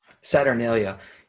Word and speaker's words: Saturnalia: Saturnalia.